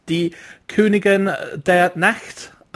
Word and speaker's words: Die Königin der Nacht: Now, let's talk about your new single, which we played a few minutes ago, and I think I'm pronouncing this right. It's The Queen of the Night, Die Königin der Nacht, I think. – Yes, wonderful. Wow.